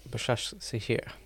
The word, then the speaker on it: Başakşehir: Başakşehir? – Başakşehir.